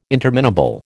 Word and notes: interminable: American English

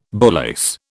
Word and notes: bullace: British English

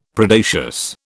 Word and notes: Predacious: British English

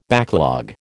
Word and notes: backlog: American English

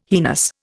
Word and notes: heinous: Indian

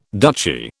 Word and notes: duchy: British English

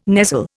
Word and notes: nestle: Indian

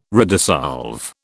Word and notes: redissolve: British English

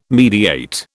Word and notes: mediate: British English

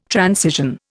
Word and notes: transition: Indian

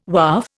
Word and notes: Waaf: Indian

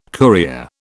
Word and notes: courier: British English

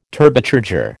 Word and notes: Turbocharger: American English

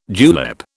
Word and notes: julep: British English

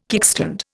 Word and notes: kickstand: Indian

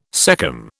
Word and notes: cecum: British English

